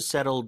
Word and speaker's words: settle: Settle 중간에 TT는 리을 발음처럼 바뀝니다. 설탄음이죠. Settle, settle down.